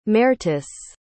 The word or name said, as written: mertice